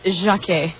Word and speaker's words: Jacque: Her name is Jackie. Is that how you pronounce it? J-A-C-Q-U-E? Jackie? – Yeah, she's French. – Actually, Jacquet.